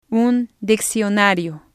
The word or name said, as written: un diccionario